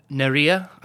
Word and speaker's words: Nérija: And you're also in Nérija. I, I hope I'm saying that right. – Yep, you. Yep, that's correct.